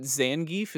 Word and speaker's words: Zangief: Like, is it Zangief?